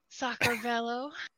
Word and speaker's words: Saqartvelo: From the history of Sacramento, Georgia. I don't know how to say that. – You said it right.